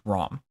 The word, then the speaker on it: Ram: The mantra, so as you're sitting in your meditation and you're doing your ohms, the mantra for this chakra is wrong. It's R-A-N-G, but it's actually spelled R-A-M. So it's pronounced wrong, but it's ROM.